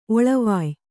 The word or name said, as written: ಒಳವಾಯ್